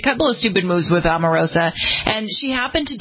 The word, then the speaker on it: Omarosa: So, yesterday we were talking, it was yesterday, the day before, about Omarosa. I pronounced her name. – Omarosa. – Right. Omarosa. She's suing LaToya.